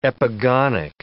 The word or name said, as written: epigonic